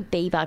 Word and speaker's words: Bieber: Is it Bieber or Bieber? Because I always Bieber. – Say it wrong. It's Bieber, right.